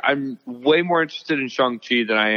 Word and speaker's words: Shang-Chi: Is it Shang-Chi or Shang-Chi? – Shang-Chi. Shang-Chi is the correct pronunciation.